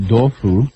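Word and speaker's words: dofu (豆腐): There's also tofu, of course, in Chinese pronounced dofu,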